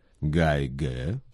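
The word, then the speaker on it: Гайгэ (改革): Политика реформы и открытости в китайском языке звучит как «кайгэ кайфанг чэнцэ». «Кайгэ» – реформа. «Кай» произносится в третьем тоне, «гэ» – во втором тоне. Слово «кайгэ» переводится как «реформа». – «Гайгэ» – реформа.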